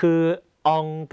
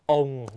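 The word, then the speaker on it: ông: ององกับองนะฮะตัวนี้คนไทยออกเสียงผิดเยอะนะครับคำว่าองภาษาเวียดนามแปลว่าปู่นะฮะคนไทยชอบออกเสียงว่าององนะฮะจริงๆไม่ใช่จริงๆต้องออกเสียงว่าองนะครับต้องทำปากก้องนะครับแล้วเวลาพูดเสียงสุดท้ายต้องปิดปากด้วยอีกรอบนะครับ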